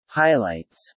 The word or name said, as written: Highlights